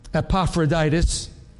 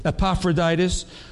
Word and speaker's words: Epaphroditus: If you look back, chapter 2:25, we have that little part about our friend Epaphroditus. I said it right. Hallelujah. Thank you, Lord. Epaphroditus, who was a messenger.